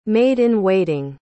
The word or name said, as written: maid-in-waiting